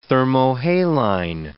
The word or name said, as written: thermohaline